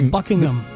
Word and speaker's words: Buckingham: Y de hecho, en inglés perfecto, el H medio que se es medio silente. Buckingham. Buckingham. – Más Palace. – O menos. – No se pronuncia mucho.